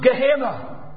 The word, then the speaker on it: Gehenna: But then there's another one pronounced Gehenna.